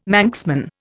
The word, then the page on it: Manxman: Indian